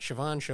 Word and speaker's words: Chauvin: Derek Chauvin. Is that how you say it? Chauvin. Like chauvinistic.